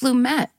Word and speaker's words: Lumet: So it's pronounced Lumet. I didn't know that. – I think so. Anytime we've said it on TCM, I feel like we've said Sidney Lumet.